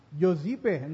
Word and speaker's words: Giuseppe: Si Giuseppe Garibaldi, Italiano po siya, Giuseppe o Giuseppe, pero sabi po dun sa pronunciation sa the pre-dictionary, Giuseppe, no?